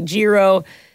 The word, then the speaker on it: gyro: Some people say gyro. And I can confirm it's gyro.